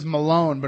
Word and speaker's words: Mellon: I believe his last name was Malone, but it was spelled like M-E-L-L-O-N.